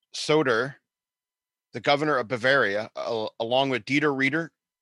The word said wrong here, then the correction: Reiter: Dieter Reiter.